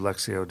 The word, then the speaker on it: Lectio: Lectio is L-E-C, believe it or not, L-E-C-T-I-O. It's pronounced Lectio for some reason. Lectio Divina, D-I-V-I-N-A, means divine reading in Latin.